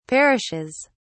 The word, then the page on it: perishes: British English